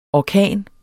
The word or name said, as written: orkan